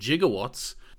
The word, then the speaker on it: gigawatt: Apparently, when Doc Brown refers to 1.21 gigawatts, that's actually just a pronunciation that was around at the time of gigawatt. It's not actually with a J, it's with a G.